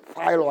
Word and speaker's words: Philo: This guy's name is Philo, I think it is pronounced, P-H-I-L-O.